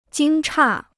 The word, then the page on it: 惊诧: jīng chà